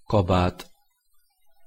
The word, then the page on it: kabat: Hungarian